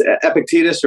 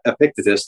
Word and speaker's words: Epictetus: It's Epictetus or Epictetus, depending on what you prefer.